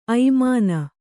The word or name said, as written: ಐಮಾನ